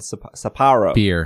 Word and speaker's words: Sapporo: Sapporo.